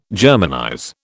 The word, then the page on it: Germanize: British English